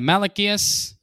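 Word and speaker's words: Malaquías: Malachias, capítulo 4.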